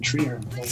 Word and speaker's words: Treherne: Nightline was a the CBC radio show, and that's our version of it, called Treherne, or as it's pronounced by the locals in that Manitoba town, Treherne.